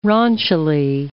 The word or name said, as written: raunchily